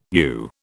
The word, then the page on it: yew: British English